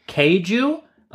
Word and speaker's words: Keiju: This is the Keiju. It's spelled K-E-I-J-U. Which, again, that's what I mean. Like, Finnish is such an interesting language. It sounds similar and sounds like kaiju, right?